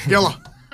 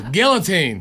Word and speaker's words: guillotine: You didn't say it right. – I know. My dad. – Gilla. – No, guillotine.